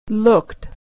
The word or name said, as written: looked